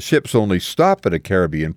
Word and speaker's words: Caribbean: Trivial note, you notice I say Caribbean as against Caribbean, because experience has convinced me that Caribbean is the right way to say it.